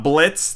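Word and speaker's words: Blitzø: And Helluva Boss is about a group of imps led by the Papa Imp Blitz, the O is silent,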